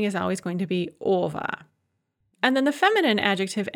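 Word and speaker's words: ova (ого): And of course, this ova is spelled o-ge-o, but pronounced ova.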